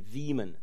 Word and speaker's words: Wieman: It's W-I-E-M-A-N. I suppose in German it'd be Wieman. But he insists that we pronounce it Wieman.